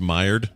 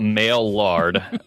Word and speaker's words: Maillard: is what Leninade in the chat room said that I was pronouncing it wrong. Oh, it's Maillard? Okay. – Yeah, so forget everything we said about male lard.